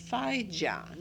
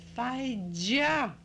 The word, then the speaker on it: Feidzia: In school, I was Faija, not Fanka, and not Faiga, and not Fei, but Faija. – Can you spell that? – Yes. In Polish, F-E-I-D-Z-I-A.